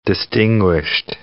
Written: distinguished

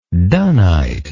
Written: dunnite

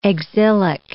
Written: exilic

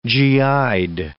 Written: GI'd

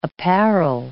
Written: apparel

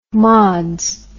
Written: mods

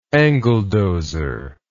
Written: angledozer

